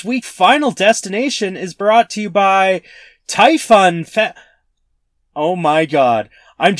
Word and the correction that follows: Taifun: just realizing right now, I think that's actually supposed to be pronounced Typhoon. It's spelt Taifun. That's what the fan is. But I think it's Typhoon.